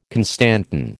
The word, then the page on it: constantan: American English